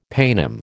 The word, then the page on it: paynim: American English